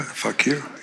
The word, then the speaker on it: fakir: the way of the fakir. – Yeah, fakir.